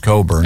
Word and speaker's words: Cockburn: It is spelled Cockburn, but it's pronounced Cockburn.